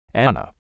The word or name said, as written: Ana